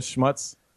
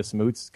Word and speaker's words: Schmutz: It's actually, I think my family changed it to like the pronunciation to Smuts because we made the CH silent just to get away from the actual fact that it means dirt in German.